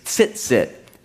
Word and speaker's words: tzitzit: The word for tassels in Hebrew is tzitzit.